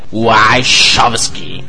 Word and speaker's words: Wachowski: Aqui vai o nome do jeito que se pronuncia: Wachowski. Como verdadeiro fã de Matrix, garanto que está certo.